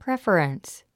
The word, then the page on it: preference: préf ə rəns; プレファレンス